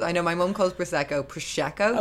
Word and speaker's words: Prosecco: It is Prosecco. That's how you say it.